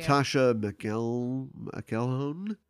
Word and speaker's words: Natasha McElhone: And then Natasha McKell, I never know how to say her name. – Okay. – Although she's herself Irish, right? Isn't she? – I have no idea. – Natasha McElhone.